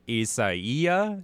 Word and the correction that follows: Isaias: I think is how it's pronounced. – Isaias. – Even though it looks like Isaiah. Oh, Isaias.